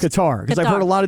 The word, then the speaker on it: Qatar: It's like guitar. Qatar. There's like an accent on it. Qatar. And you're supposed to roll the R at the end.